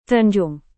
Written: tanjung

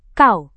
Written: kau

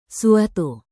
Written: suatu

